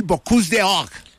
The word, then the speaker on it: Bocuse d'Or: ¿Qué es el Bocuse d'Or? Explíquele, no sé si lo dije bien en francés, mi francés está, no es de Marsella. – Está perfecto, está ahora, perfecto.